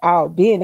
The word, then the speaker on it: Albany: And if you're from there, you have to say it like that. Albany. – Not Albany. – Not Albany. No, those are New York people.